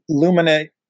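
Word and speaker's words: Luminate: Or am I even pronouncing it? Or Luminite? – Yeah, Luminite is the new sound scan.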